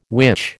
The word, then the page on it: which: American English